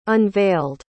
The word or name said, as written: unveiled